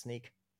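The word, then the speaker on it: Snyk: It's actually pronounced Sneak.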